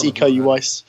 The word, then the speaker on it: Iko Uwais: Stars Iko Uwais, Stars Iko Uwais from um, hope I've said his name right uh, from The Raid. – Yep.